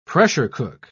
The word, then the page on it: pressure-cook: British English